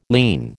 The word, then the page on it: lien: American English